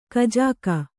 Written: ಖಜಾಕೆ

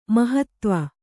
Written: ಮಹತ್ವ

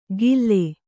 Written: ಗಿಲ್ಲೆ

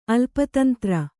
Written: ಅಲ್ಪತಂತ್ರ

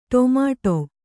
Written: ಟೊಮೆಟೊ

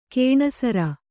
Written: ಘನಸಾರ

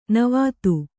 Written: ನಿವಾತ

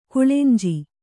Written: ಕಳೆಂಜಿ

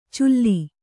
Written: ಚುಲ್ಲಿ

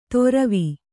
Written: ತೊರವಿ

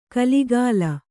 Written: ಖುಲಿಖುಲಿ

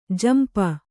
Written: ಝಂಪ

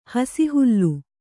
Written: ಹಸಿಹುಲ್ಲು

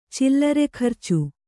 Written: ಚಿಲ್ಲರೆಖರ್ಚು

